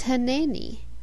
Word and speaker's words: Hineni: The modern way of pronouncing this Hebrew word is Hineni.